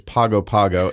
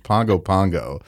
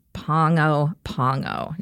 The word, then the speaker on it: Pago Pago: Tutuila is the largest island, and it has the capital on that island, which we have called for the last 10 years Pago Pago, and, which is an incorrect pronunciation. It's Pongo Pongo. – That's so, right. – It rhymes with song.